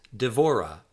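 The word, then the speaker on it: Devorah (דבורה): Word 15, the word for Deborah or bee is Devorah, Devorah, Devorah.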